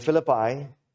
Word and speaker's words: Philippi: In particular, this point the church is addressing in Philippi, it's pronounced Philippi, not Philippi or Philippi.